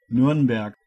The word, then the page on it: Nürnberg: German